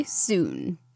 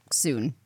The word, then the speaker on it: Sune: Part three Sune. So that's spelled S U N E, not like soon, as in, you know, soon.